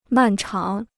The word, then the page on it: 漫长: màn cháng